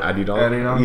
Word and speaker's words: Adidon: No, because it's supposed to be like Adidas and Don, right? So yeah. – Adidon? – Yeah.